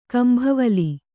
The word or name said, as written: ಕಂಭವಲಿ